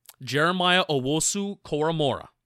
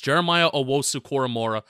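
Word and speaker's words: Jeremiah Owusu-Koramoah: J-O-K, Jeremiah Owosu-Koramora. Pronounced that right. – Did you really? Okay, because I've just been going J-O-K.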